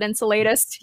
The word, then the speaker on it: Enceladus: It's called Enceladus. Mm-hmm. Some people pronounce it Enceladus.